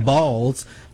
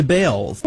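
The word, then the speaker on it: Baals: After all, the family did change the pronunciation of their last name from Balls to Bales.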